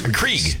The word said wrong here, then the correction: Keurig: Keurig. – Yeah. Keurig. Keurig. Yeah. – You don't even know how to say it.